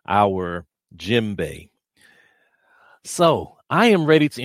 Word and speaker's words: djembe: You see the djembe? Pronounced with a silent D.